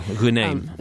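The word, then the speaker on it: Ghonim: I was struck by Wael Guenaim. Is that how you pronounce his name? The Google activist who was released yesterday. – Yeah, Guenaim.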